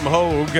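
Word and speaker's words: Hoge: Adam Hoagie or Hogue? Excuse me. I don't know how to pronounce the last name.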